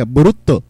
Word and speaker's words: Boruto: o sea Bolt, pero en japonés se pronuncia Boruto.